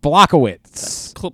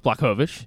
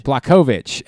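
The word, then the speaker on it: Błachowicz: Jan Blachowicz. – Pretty close. Blachowicz. – Blachowicz.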